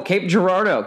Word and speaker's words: Cape Girardeau: Oh, Cape Girardeau.